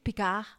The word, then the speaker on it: Picard: Yeah, but it's spelled Picard. – Okay. – It's Picard, yeah. I guess. you don't pronounce the D like everything in France.